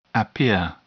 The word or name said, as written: appear